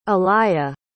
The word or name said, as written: alaïa